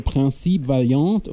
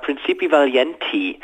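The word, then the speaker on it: Principe Valiente: Wie spricht man denn die bitte schon aus? Also Principe Valiant oder was? – Also wenn man es französisch machen würde, dann wärst du jetzt goldrichtig. Ich glaube allerdings, das ist eine englische Variante. Und ich würde sagen Principi Valienti.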